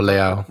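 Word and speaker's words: Leão: We did get to see, how do you say it, Leao? Is that? – Yeah, Rafael Leao.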